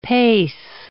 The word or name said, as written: pace